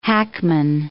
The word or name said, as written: hackman